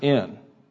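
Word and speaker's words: in (ἐν): The particular Greek preposition that is here translated by the New American Standard, by, is it's pronounced in. spelled Epsilon Nu, or E-N.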